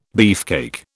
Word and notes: beefcake: British English

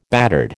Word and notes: battered: American English

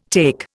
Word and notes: take: Indian

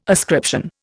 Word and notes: ascription: Indian

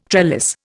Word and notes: trellis: Indian

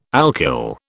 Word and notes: alkyl: British English

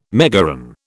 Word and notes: megaron: British English

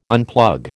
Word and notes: unplug: American English

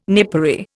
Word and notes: napery: Indian

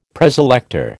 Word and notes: Preselector: American English

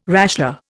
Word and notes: rasher: Indian

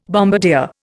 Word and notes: bombardier: Indian

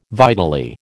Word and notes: vitally: American English

